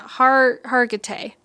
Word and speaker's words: Hargitay: who is played by Mariska Hargitay. I think I said that right. Yeah, Hargitay.